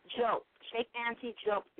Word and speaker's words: Diop: Not Diop. It's not Diop. It's Chope. – Spell what you're saying. – It's the same thing you're saying, but you're pronouncing it wrong. It's Chope, not Diop.